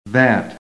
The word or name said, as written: THAT